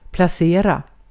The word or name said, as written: placerar